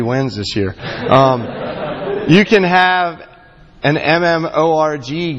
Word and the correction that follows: MMORPG: game righteousness. Can anybody tell me what I just said? Did I say it wrong? – Yes. – What is it? – MMORPG. – Can you tell me that acronym? – Massively multiplayer online role-playing game.